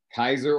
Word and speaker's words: Keizer: Kaiser, Oregon.